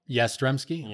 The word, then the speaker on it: Yastrzemski: Number nine, how do you say his name? Carl Yastrzemski. – Yeah, Yastrzemski.